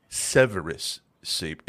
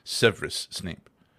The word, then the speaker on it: Severus: Even J.K. Rowling says that it is a soft E. It is not Severus Snape. It is Severus Snape. – Okay. – So she's mistaking me saying Severus Snape.